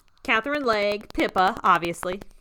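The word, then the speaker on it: Legge: Catherine, we've never come down on how we pronounce. Is it? – It's leg. – Okay. Catherine Leg. – I just like to say leggy, but it's leg.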